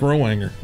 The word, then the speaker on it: Rowanger: How would you say it, fat boy? R-O-W-A-N-G-E-R. – I think it's Mike Rowanger.